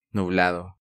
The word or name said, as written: Nublado